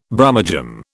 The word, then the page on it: Brummagem: British English